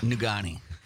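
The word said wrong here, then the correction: Negaunee: why can't you pronounce words? Nigani. – That's Nigani.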